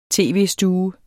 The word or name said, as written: tv-stue